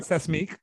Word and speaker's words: Seismique: And it's called, I might pronounce it wrong, but it's called Seismic, S-E-I-S-M-I-Q-U-E. – That feels right. Seismic.